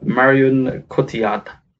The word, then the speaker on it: Marion Cotillard: Let's start with the ladies and with Marion Cotillard. – Yeah. – That's how you pronounce it.